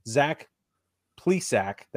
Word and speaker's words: Zach Plesac: We've got Ed Rosario, Shane Bieber, Cal Quantrill, Josh Naylor, Zach Plesac, that's how you say his name, Aaron Civale, and James Karinchak.